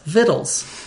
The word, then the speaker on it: victuals: And it used this word, V I C T U A L S, with a little footnote with a hint about how to pronounce it as "vittles."